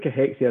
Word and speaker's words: cachexia: But it's C-A-C-H-E-X-I-A is how you spell it. And the correct way to pronounce it is cachexia, but a lot of people will drop that the second C.